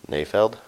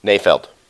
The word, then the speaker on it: Nayfeld: How do you say his last name? – Nayfeld? There's no I in there. – Nayfeld? – Yeah. – Nayfeld.